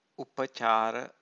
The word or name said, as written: upacara